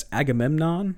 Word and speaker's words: Agamemnon: They're getting the HMS Surprise, the HMS Agamemnon. I can say that word. – Good pronunciation. Good.